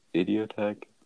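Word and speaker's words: Idioteque: Well, I liked Idiotech. That's how you say it.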